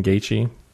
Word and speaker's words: Gaethje: But yeah, so last night was Ultimate Fight Night, Gaethje versus Vic, Justin Gaethje. There you go. That's how you say it, Gaethje.